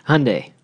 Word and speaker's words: Hyundai: Hyundai. Rhymes with Sunday.